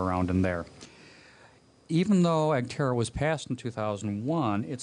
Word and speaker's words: EGTRRA: The Roth 401k was created by the Economic Growth and Tax Reform Reconciliation Act EGTRRA, which is pronounced EGTERRA, I believe.